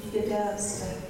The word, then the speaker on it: Gedamsta: The name most people use today, Skidams, is actually the English pronunciation of our clan's chief, Gedamsta.